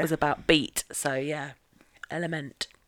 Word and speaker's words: LMNT: Like, unless I'd read somewhere that it's so L-M-N-T, but you pronounce it element, I'd have been like, LMNT? – Yeah.